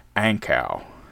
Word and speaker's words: Ankou: Before the Old World Underground was Ace, see, I pronounced them right, Ace with their new single, Ank, those motherfuckers, they're always after me. They try to make me look stupid, throwing shit at me I can't pronounce. Ankau, A-N-K-O-U, Ankau very much.